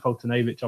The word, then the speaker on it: Foltynewicz: So next up on Friday, we have Steven Strasburg against Mike Foltynewicz. Steven Strasburg 6-4 with a 3.13 ERA. Mike Foltynewicz 4-3 with a 2.55 ERA. If you couldn't tell, I have Mike Foltynewicz on my fantasy team, so I know how to say his name.